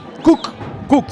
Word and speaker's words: Cook: El árbitro se pronuncia tal cual como se pronuncia cocinar en inglés. Cook.